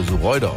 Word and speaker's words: Sureide: Ich wusste ja vorhin nicht, dass Sureude Sureude ausgesprochen wird. Sie schreibt, unser Dorf wird tatsächlich Sureide geschrieben, aber Sureude gesprochen.